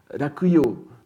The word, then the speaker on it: Rakuyo: Vous savez que, par exemple, Kyoto a comme autre nom, poétique et littéraire, Rakuyo, c'est-à-dire la prononciation japonaise de Luoyang.